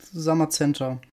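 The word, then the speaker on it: Zamazenta: Ähm, wie spricht man die nächsten beiden Pokémon aus? – Ich denke mal Satian. irgendwie sowas in die Richtung. – Mhm. – Und Samazenta. Satian das Schwert-Pokémon und Samazenta das legendäre Schild-Pokémon.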